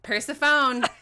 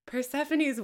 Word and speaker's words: Persephone: For a really long time, I had never heard Persephone pronounced. I'd only ever read it. And so... – Persephone. – Until I was 14. – Yeah, same. – And then I heard it and I was like, Persephone's way prettier than Persephone.